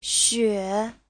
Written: xue3

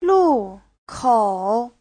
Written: lu4kou3

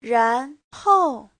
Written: ran2hou4

